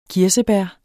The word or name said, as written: kirsebær